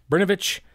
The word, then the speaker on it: Brnovich: There's no vowel right there. It's B R N. How do you pronounce that one? Bern? Brnovich. Brnovich v.